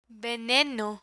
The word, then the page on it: veneno: Spanish